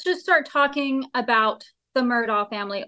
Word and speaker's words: Murdaugh: But I really think it's the Murdaugh. I mean, I have heavily researched it, and I'm pretty sure it's a Murdaugh.